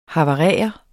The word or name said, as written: havarere